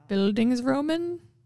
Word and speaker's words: Bildungsroman: Bildungsroman? Did I say that right? – Yes.